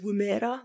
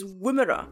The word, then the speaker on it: Woomera: I pronounced that as Woomera last week, but I've now watched a couple of YouTube videos and apparently it's Woomera.